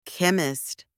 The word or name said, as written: chemist